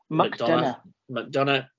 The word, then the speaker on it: McDonough: JD Madonna, is that how you pronounce it? – McDonough.